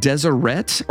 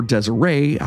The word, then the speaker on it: Deseret: The state of Deseret or Deseret, I'm not real sure how to pronounce this one here. It's D-E-S-E. – It's dessert, man.